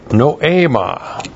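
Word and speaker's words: noema (νόημα): That's N-O, long E-M-A, noema.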